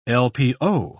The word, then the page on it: lpo: British English